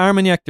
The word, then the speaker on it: Armagnac: Is am I pronouncing that right? – Yeah, Armagnac.